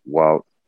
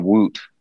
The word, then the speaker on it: Wout: That stage with Mount Ventoux, the two laps just the other day, what an incredible ride for Wout. I always call him Woot, but it's Wout Van Aert, I guess.